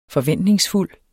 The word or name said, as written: forventningsfuld